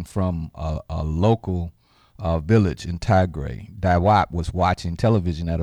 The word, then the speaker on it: Tigray: That's spelled T-I-G-R-A-Y. Yeah, Tigray. There's a, you know, important kind of thing that's been going on in Tigray.